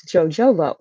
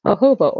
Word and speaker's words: jojoba: Now, if you're like me, you probably mispronounced this when you first came across the name of this oil because it's spelled Jojoba, and it looks like it's jojoba oil, but it's actually pronounced jojoba oil.